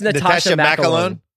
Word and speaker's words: Natascha McElhone: Nakasha McElhone. How do you say it? – I think it's Natasha McElhone. Yeah.